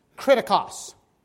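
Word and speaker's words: kritikos (κριτικός): It's pronounced kritikos. What does that sound like? Kritikos. Critical, right? And that's where we get our word, critic, from.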